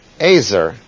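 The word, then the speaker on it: Azer (עֵזֶר): And Azer should really be pronounced. Azer is a word that means helper.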